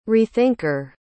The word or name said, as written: rethinker